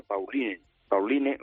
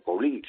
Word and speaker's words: Pauline: Esta se llamaría Pauline o Paulín, según lo quieras pronunciar, se escribe Pauline.